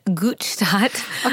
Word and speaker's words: Gutstadt: Well, on Instagram, you could find me at Gutstadt. – Okay. I'm going to go find you right now. – Yeah. Actually. I'm going to spell that for you guys because, you know, complicated. Gutstadt.